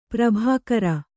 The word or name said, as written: ಪ್ರಾಭಾಕರ